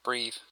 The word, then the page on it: breve: primary